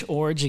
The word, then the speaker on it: Iorg: Nash Iorg again. It is a unique last name for sure. Tough to say, and it always gets pronounced incorrectly.